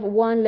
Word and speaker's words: one: These sounds are hard to pronounce because, especially in Persian, we have one letter for both. I have heard people saying van. Number one, van, as van, instead of one.